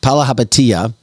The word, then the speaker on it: Palihapitiya: Just listen to this Democrat billionaire, Chamath Palihapitiya. I'm trying to pronounce his name right. Palihapitiya.